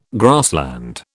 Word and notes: grassland: British English

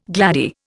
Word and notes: gladdie: Indian

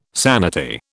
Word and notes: sanity: British English

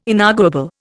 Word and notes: inarguable: Indian

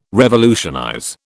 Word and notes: revolutionize: British English

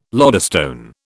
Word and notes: lodestone: British English

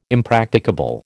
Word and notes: impracticable: American English

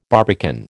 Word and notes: barbican: American English

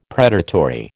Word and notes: predatory: American English

